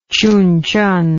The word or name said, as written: Chunchon